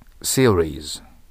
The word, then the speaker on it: series: series シリーズと発音します。だから日本シリーズじゃないです。